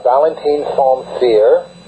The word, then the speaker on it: Valentin Saint-Cyr: It's actually Valentin Saint-Cyr, and it's a sort of a bastardized French,